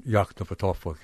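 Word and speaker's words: Yoknapatawpha: Down in Yaktopatawpha County. – Right. – Did I say that right? – That's roughly how I say it, I guess.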